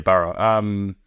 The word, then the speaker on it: Burra: When did you start thinking about transitioning yourself from sport into, I mean, you've got the coffee shop, you got Burra, is that how you pronounce it, Burra? – Burra.